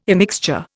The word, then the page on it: immixture: Indian